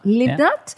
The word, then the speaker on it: Livnat: Then we have Daniel Livnat. Did I say that right? – Yeah, Livnat. – Livnat.